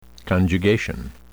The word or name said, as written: conjugation